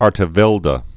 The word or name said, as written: Artevelde